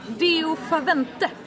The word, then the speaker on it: Deo Favente: Hur uttalar man ett live? Deo Favente. – Yes.